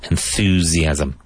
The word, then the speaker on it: enthusiasm: Both S's in that word sound like a Z. Enthusiasm.